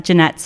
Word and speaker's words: Jeanette: So it's at Jeanette Stock, and Jeanette is JEANETTE.